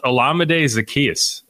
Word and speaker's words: Olamide Zaccheaus: You know, the other thing that we're familiar with is how to pronounce Olamide Zaccheaus.